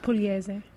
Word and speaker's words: Pugliese: No, because nobody ever pronounced it properly. It would be like Pugliese. One time I even got pole juice.